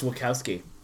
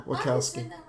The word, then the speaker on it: Wachowski: This is a Wachowski thing. – It's pronounced Wachowski. – Oh, is it? – Yeah. – Oh, I didn't know that. – Yeah. – Wachowski.